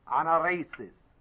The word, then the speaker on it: anaersis: A-N-A-E-R-S-I-S. Anaresis.